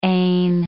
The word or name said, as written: ane